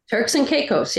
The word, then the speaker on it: Turks and Caicos: Turks and Caicos.